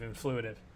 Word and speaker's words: Influitive: Before that, he worked at Nudge.ai and, how do you pronounce this, Influitive? – You got it. It's like Influence plus Intuitive, Influitive.